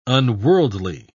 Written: unworldly

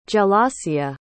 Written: gelasia

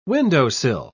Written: windowsill

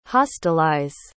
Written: hostilize

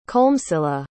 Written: colmcilla

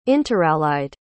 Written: interallied

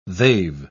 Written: they've

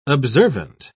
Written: observant

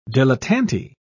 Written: dilettante

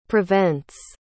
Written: prevents

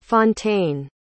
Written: fontane

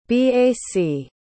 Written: bac

